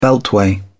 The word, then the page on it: beltway: British English